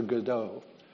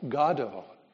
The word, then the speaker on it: Godot: It is a reference to Thomas Beckett's absurdist play, Waiting for Godot, or Godot, as Beckett himself said the name should be pronounced.